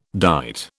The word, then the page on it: dight: British English